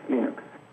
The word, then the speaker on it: Linux: And I pronounce Linux as Linux.